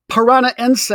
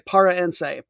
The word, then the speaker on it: Paranaense: These two teams have only played 3 times, 1-1-1, perfectly even. Let's look at them. Parana Ense. I always want to stop a syllable short there and say Para Ense.